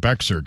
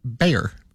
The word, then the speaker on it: Bexar: And it's in Bexar County, which a lot of people think is called Bexar County. – Yeah, Bexar is Bear.